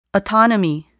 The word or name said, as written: autonomy